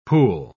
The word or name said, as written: pul